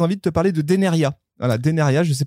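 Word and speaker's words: Dénériaz: Et vous remarquerez que Deneria, il y a un Z à la fin, ça ne se prononce pas, chers amis.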